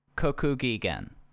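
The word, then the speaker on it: Kokugikan: This is the Revolution Rumble 1994 in Ryogoku, Kokokigaden. I always mess up pronouncing it. It's at Sumo Hall. Sorry. – I can't believe people just don't say Sumo Hall. It's the only way I'll ever refer to it. – Kokugigen. There we go.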